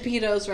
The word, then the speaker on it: Pitos: And Pitos rhymes with Cheetos.